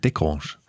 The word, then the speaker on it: de Grange: Sie sagen de Grange, aber im Saarland... – Ja. – Genau, im Saarland sagt man, betont man es eher vorne, sagt man Thorsten de Grange.